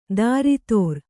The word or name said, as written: ದಾರಿತೋರ್